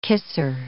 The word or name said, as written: kisser